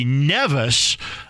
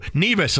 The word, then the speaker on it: Nevis: I did not have much of a chance to bring up St. Kitts and what I would say Nevis until the last, say, few days. Then I heard the broadcasters go, Nevis, and I go, I guess I've been saying this wrong,